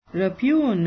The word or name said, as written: repugn